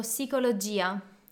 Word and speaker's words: psicologia: Prima tu hai detto psicologia. – Psicologia. – Ah, Avevo detto la P. – P. Ragazzi, la, pronun- la P si pronuncia in, in italiano, non è psicologo o psicologo è psicologo, psicologia.